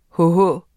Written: hh

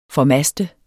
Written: FORMASTER

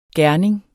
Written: gerning

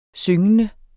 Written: syngende

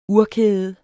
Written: urkæde

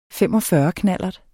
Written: knallert

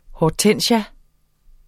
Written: Hydrangea

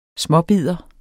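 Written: SMÅBIDDER

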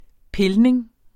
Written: pilning

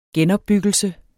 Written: genopbyggelse

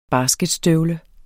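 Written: basketstøvle

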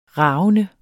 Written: ravende

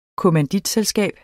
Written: kommanditselskab

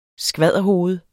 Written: skvadderhoved

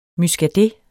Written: muscadet

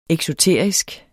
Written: eksoterisk

